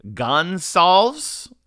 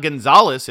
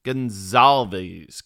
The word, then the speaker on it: Gonsalves: It's not Gonzalez. It's Gonsalves? Maybe it is Gonzalez, and it's just pronounced differently. I don't know. I'm going with Gonzalez. That's what I'm going. But it's a V, not a Z at the end. Gonsalves.